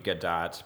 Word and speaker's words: Gadot: It's supposed to be Gadot, because it is Gadot. Not. Yeah, it's not. Most people say Gadot because we want to go French on it, but she's actually Israeli, so no French behind it.